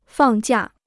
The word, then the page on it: 放假: fàng jià